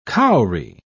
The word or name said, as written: cowrie